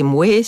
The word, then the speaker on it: moi: À l'époque, on disait le roué, c'est moué, c'est Louis XIV, et cette prononciation en oué était mieux vue, et roi et moi, qui sont les prononciations actuelles, n'étaient pas forcément bien vues, mais elles ont fini par l'emporter.